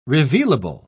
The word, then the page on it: revealable: British English